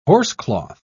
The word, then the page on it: horsecloth: British English